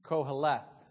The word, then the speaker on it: Koheleth: It's the words, and then koheleth is the way you would pronounce it.